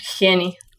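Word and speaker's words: Jenni Hermoso: Is it Jenny Hermosa? Henny Hermosa? – Henny. – Henny.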